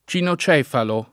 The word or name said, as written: cinocefalo